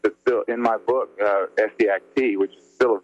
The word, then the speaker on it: Essiac: C-A-I-S-S-E, if you spell that backwards, it's E-S-S-I-A-C, and that's Essiac, or Essek,